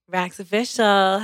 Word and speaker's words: Raxx Official: Raxx Official.